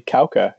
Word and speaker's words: Kawka: Kauka is how you pronounce it.